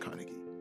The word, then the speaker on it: Carnegie: You know, there's this book by Dale Carnegie. Okay, yeah, it's pronounced Carnegie, right? Yes, How to Make Friends and Influence People.